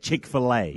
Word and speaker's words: Chick-fil-A: Chick-fil-A.